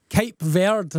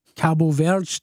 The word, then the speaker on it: Cabo Verde: Champion squeezing by Cape Verde, as I'm told to pronounce it, or Cabo Verde, depending whether you're saying it in English or Portuguese.